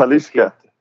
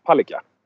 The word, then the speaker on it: Palicka: Då är vi inte helt beresta kanske som svenskar hur man ska uttala det. Men då uttalar jag som alla andra människor någonsin i hela världen har sagt. Och de säger Palicka.